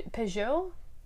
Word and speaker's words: Peugeot: So we we rented a car, brand new car, Pe- Peugeot.